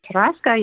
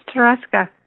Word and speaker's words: Tarasca: Tanika, did I get your name right? Hopefully I pronounced it right or got close. – Tarasca, yep. – Oh, I'm sorry. My auto screen had your name totally wrong. – It's Tarasca. – Tarasca. Well, that's unusual. – T-H-E-R-E-S-K-A.